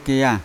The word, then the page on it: keya: Yawa